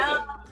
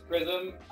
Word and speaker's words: PRZM: PRZM. Yeah, it's PRZM. Jarrell works too, whatever you want. But yeah, PRZM is PRZM. It's pronounced PRZM. – PRZM.